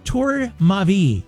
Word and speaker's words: Tour Ma Vie: but I finally know how to say it. Tour Ma Vie. It means my life tower in French.